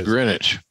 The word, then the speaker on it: Greenwich: He put Greenwich, but yeah, Greenwich. There you go. – Yeah, well, it's spelled Greenwich. It is Greenwich.